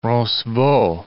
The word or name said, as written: Roncevaux